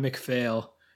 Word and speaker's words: McPhail: It's like, if you're saying it out loud, it's spelled P-H-A-I-L, but it's pronounced John McPhail, according to the way they're pronouncing it in the documentary and in the commentary.